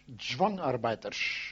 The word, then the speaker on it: Zwangarbeiter: Zwang is forced. In Holland, they call it dwangarbeiters. In German is zwang. We pronounce it with a D and they with a Z, zwang. But we say dwangarbeiters and say zwangarbeiters. You see, that's the difference.